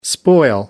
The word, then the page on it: spoil: American English